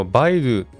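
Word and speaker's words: 百度: はい、またですね、中国のバイドゥ、ね、 100 度と書いてバイドゥと読むんですけども、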